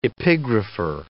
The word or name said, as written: epigrapher